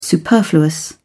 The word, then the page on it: superfluous: British English